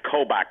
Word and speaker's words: Kobach: Well, first of all, let's place this guy, Kobach, and it's spelled K-O-B-A-C-H, pronounced like Coke with a B-A in the middle.